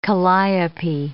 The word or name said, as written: calliope